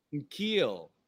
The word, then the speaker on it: N'Keal: N'Keal. N'Keal. It's the N'Keal. N'Keal. Yeah, see? I didn't kill him. – Yeah. – N'Keal. Yeah, I don't like that name. – Yeah. N'Keal.